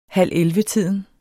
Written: halvellevetiden

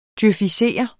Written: djøfisere

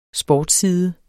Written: sportsside

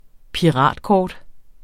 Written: piratkort

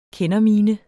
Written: kendermine

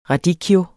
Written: radicchio

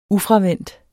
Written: ufravendt

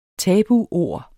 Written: tabuord

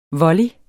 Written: volley

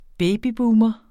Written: babyboomer

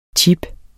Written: chips

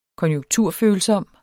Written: konjunkturfølsom

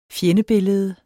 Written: fjendebillede

